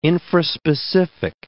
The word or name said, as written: infraspecific